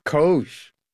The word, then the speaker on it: COGE: How do you pronounce the acronym for this commission? – COGE. – COGE. – COGE. It is exactly what you think it is, Steve. And I know what's in the back of your mind when you ask that question. – Go on. What is in the back of my mind? – It does rhyme with Doge.